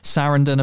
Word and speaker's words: Sarandon: Sarandon. Apparently, I get laughed at when I go on American podcasts, and they go, "Oh, that's not how you say it." but apparently, it's supposed to rhyme with abandon.